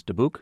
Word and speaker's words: Dubuc: It says a very summary, very good book called Goodnight, Layla Tove, Layla, L-A-I-L-A-T-O-V, by Laurel Schneider. Also, The Lion and the Bird by Mary Ann, Dubuc, D-U-B-U-C.